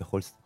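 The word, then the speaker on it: Holst: Давайте послушаем Густава Хёльст, правильно произносить? – Да? По-немецки Хольст.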